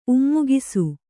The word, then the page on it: ಉಮ್ಮುಗಿಸು: Kannada